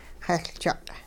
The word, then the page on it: xehLjuq': hupa